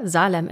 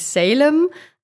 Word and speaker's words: Salem: Und weil das im Folgenden immer mal wieder eine Rolle spielen wird, sagen wir an der Stelle einmal, dass wir uns für die deutsche Variante Salem entschieden haben, also die deutsche Aussprache. Auf Englisch heißt es natürlich Salem, aber weil in vielen deutschsprachigen Dokus eben die deutsche Aussprache genommen wird, bleiben wir jetzt auch dabei.